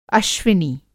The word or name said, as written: अश्विनी